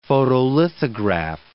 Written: photolithograph

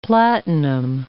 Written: platinum

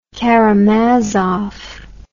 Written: Karamazov

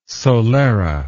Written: solera

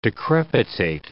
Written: decrepitate

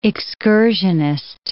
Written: excursionist

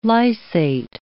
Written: lysate

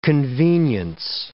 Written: convenience

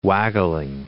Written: waggling

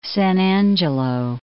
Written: San Angelo

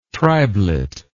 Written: tribelet

